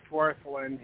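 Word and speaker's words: Wirthlin: Worthrin? I can't pronounce that very well. IBID, page 20. – It's just Worthrin.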